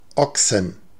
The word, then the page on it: Ochsen: /ˈɔksən/